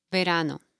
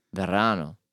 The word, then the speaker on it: verano: Verano. – Verano.